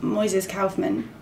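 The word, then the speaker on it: Moisés Kaufman: And the playwright Moises Kaufman, am I saying that right? – Yeah.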